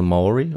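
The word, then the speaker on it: Māori: Man nennt sie auf Deutsch Maori. Ah, ja. Oder in deren ja. Sprache spricht man es ein bisschen anders aus, eher so Maori oder ja. Maori.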